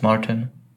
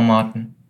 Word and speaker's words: Martin: It's Martin. Or Martin, something like that, yeah. – Martin sounds very American, actually. So Martin in German? – Yeah.